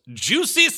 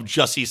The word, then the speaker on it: Jussie: But he does talk about who he calls Juicy Smollett and a very McWilliams esque pronunciation of Jussie Smollett